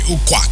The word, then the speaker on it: Ukwak: Okay, Ukwak is how they say it in Akwa Ibom.